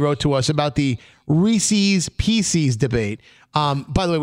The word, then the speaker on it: Reese's: Reese was a person, apostrophe S. – And they were his. – And they were his pieces. Reese's Pieces. That is the proper pronunciation, yes.